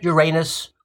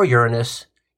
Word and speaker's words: Uranus: and the planet Uranus or Uranus. Either way you pronounce it, it's an odd name.